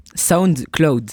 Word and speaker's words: SoundCloud: Comment on prononce SoundCloud? Tu connais ça? – SoundCloud. – Ouais, SoundCloud. Moi, j'y arrive pas. Hier, c'est Laura qui disait à ma place. Merci. Parce que je dis toujours SoundCloud.